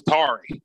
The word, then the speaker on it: Tari: And is it Tari or Terry Eason from LSU? – I've always thought it was Tari. That's how I pronounce it.